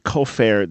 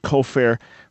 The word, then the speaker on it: kofer: You said that you mentioned the Hebrew word kofer. There is one Hebrew word kofer with the accent on the first syllable, which is a certain type of payment or atonement.